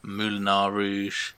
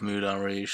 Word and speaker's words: Moulin Rouge: He's saying Kvossieri, and he also says Moulinar Rouge instead of Moulin Rouge.